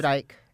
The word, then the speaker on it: Icke: David Icke.